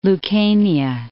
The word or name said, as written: Lucania, Mount